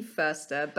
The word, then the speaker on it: Förster: I would say Marie Förster, but I get that that is really hard to say if you're not a German.